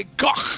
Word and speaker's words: Gogh: Vincent Van Gogh. You're supposed to say Gogh. That's how they're supposed to be pronounced, I found out when I was in Europe.